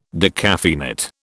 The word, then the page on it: decaffeinate: British English